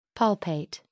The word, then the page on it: palpate: American English